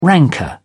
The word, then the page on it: rancour: British English